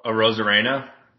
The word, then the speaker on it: Arozarena: Arozarena.